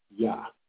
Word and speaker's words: Jah: With which are Rastafarian brothers, but when you actually look it up, it says that it's pronounced Yah.